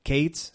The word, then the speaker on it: Cates: Sí, Isaac como en español, pero también luego Cates se escribe C-A-T-E-S.